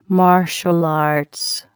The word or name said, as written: martial arts